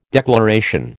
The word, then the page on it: declaration: American English